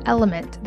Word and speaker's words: LMNT: This podcast is sponsored by Element. That's spelled L-M-N-T.